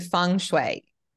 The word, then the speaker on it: feng shui: How did you pronounce it just then? You said feng shui. – Feng shui. – Oh, see, I said it in such an Aussie bogan way.